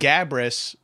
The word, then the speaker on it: Gabrus: If you said Gabrus, that's completely correct. – Yeah. – You know what I mean? Like, that's hard. Like, I wouldn't be like, no, you idiot. Mm-hmm. That's reading it phonetically.